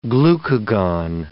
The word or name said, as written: glucagon